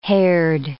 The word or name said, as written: haired